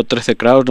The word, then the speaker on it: 13Krauss: Pero un poco yo estoy diciendo 13 Kraus, no sé si se pronuncia así, si... – Correcto. – Ah, tal como, Alfredo. Como, como, suena, ¿no?